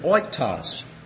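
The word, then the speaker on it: oiktos (οἶκτος): It is O-I-K-T-I-R-M-O-S. Pronounced oiktirmos. Now, the word comes from a word oiktos. Just this portion here. O-I-K-T-O-S. Oiktos. Oiktos means pity.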